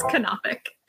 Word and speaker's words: Knopic: It's Knopic. – Is it Knopic? – Yeah. – Son of a gun. It's a silent K.